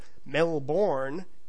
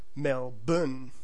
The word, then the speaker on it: Melbourne: Basically, the rule here is that any time an Australian city has a B and an NE at the end of its name, you can just go ahead and drop all the letters in between. So instead of Melbourne, it becomes the more accurate Melbourne. Instead of Brisbane, you have Brisbane.